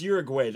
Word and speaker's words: Uruguay: But it's Uruguay. That's the pronunciation.